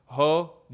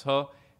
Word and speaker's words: JOH: who's known in Honduras and Latin America overall as Ho, J O H, which you pronounce Ho.